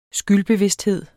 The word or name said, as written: skyldbevidsthed